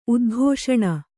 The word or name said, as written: ಉದ್ಘೋಷಣ